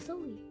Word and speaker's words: Zoe: I need, either call me Zozo or Zoe.